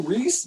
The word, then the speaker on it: Rhys: Reese Nicholson. – Ooh, Reese Nicholson. – R-H-Y-S Nicholson.